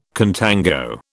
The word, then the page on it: contango: British English